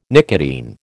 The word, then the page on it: nicotine: American English